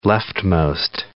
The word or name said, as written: leftmost